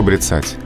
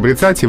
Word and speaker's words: бряцать: Брицать оружием, а не бряцать. Да, это очень частый вопрос. Э, вызывает этот глагол трудности, связанные с ударением. Только брицать только брицать, и в личных формах тоже брицаю, брицаешь, ударение там же остается.